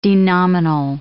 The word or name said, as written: denominal